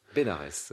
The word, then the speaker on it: Bénarès: Elle passe 9 mois à étudier le sanskrit à Bénar. – Bénarès, parce que c'est dans le sud. – On prononce toutes les lettres, monsieur Bénarès.